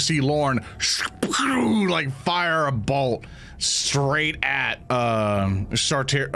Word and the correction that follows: Sardi: I can't even say his name anymore. It's Sardi.